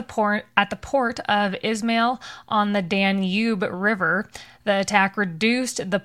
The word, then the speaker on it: Danube: All right, you pronounced Danube right today. The people, the keyboard warriors with the pitchforks got really mad last time.